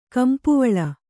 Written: ಕಂಪುವಳ

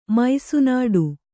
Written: ಮೈಸುನಾಡು

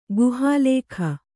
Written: ಗುಹಾಲೇಖ

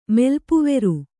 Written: ಮೆಲ್ಪುವೆರು

